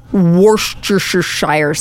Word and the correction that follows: Worcestershire: No, you didn't. No. You added like an extra "er" in there. – Hmm. – It's just Worcestershire sauce.